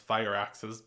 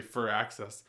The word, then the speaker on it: Firaxis: So there's a certain point in Civilization made by the same company, which I want to pronounce Fireaxis, but is actually Firaxis.